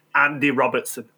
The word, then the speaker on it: Andy Robertson: It's definitely Andy Robertson.